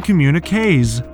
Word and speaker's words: communiqués: You simply add an S to the end, making communiqués. Just remember that this final S is pronounced like Z in English, but remains silent in French.